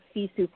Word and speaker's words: Sisu: So let me talk about the word Sisu for a minute, because it may or may not be familiar to people listening. So Sisu is spelled S-I-S-U. It's actually a Finnish concept.